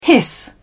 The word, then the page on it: hiss: British English